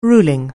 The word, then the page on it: ruling: anglais